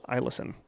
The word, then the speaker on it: Eielson: She says, it looks like Eielson, but she said the first E is pronounced as an I, so Eielson.